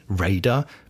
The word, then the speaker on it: Raider: Außerdem würde man Raider, englisch ausgesprochen Raider, mit Räuber übersetzen.